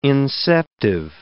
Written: inceptive

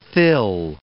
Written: thill

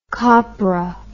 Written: copro

